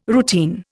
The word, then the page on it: routine: Indian